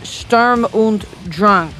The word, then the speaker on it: Sturm und Drang: There's a new Lamb of God album coming out July 24th called Seven Sturm und Drang. Am I saying it right? – That's close enough.